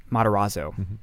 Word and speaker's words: Matarazzo: He sang Matarazzo.